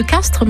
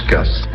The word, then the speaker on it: Castries: Quand on parle de village de Castries en langue d'oc, dans la vieille langue du pays, on prononce Castres. Et comme au XVIIe siècle, cette langue était beaucoup plus parlée ici que le français, on a conservé l'usage de la prononciation ancienne.